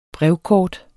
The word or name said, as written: brevkort